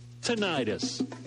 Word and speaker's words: tinnitus: Kentucky Audiology and Tinnitus Services wants to know how you say tinnitus. – Incorrect. Look it up. It's pronounced tinnitus.